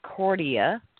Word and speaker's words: Cordia: I am a graduate of Cordelia School. I hope I said that right. – The way it's spelled. It's pronounced the way it's C-O-R-D-I-A. Cordia.